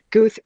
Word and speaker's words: Goode: So on my email signature, I say Goode rhymes with truth, just so that people know how to say it.